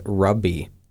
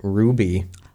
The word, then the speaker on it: ruby: The first one I remember was rubby, which was ruby.